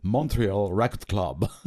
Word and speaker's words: Montreal Racquet Club: Là, je vais la prononcer à l'anglaise, Montreal Racquet Club, parce que hein, c'est un club anglais.